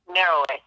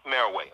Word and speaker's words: Meroe: Now, um, when we look at uh, uh, is the correct pronunciation Moreau? – Meroway. – Meroway. Okay, M E R O E.